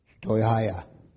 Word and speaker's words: stoicheia: It is a word in the Greek, and it's spelled S-T-O-I-C-H-E-I-A, and it sounds like stoicheia.